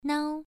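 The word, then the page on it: nao: tone 1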